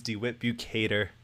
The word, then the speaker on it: DeWitt Bukater: Opinions on Titanic's Jack Dawson and Rose DeWitt Bukater.